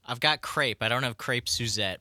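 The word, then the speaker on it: crêpe: In French, we would say crepe. – Crepe. – The S at the end in French is silent, but in English or American, it'd be fine to say crepes. Crepes.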